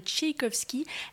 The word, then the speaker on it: Tchaïkovski: D'ailleurs, justement, j'ai pu vérifier la prononciation. Il s'agit donc bien de Tchaïkovski pour le romantisme, mais également de Haydn pour la musique classique.